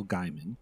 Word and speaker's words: Gaiman: Writer's name is Neil Gaiman.